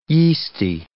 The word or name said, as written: yeasty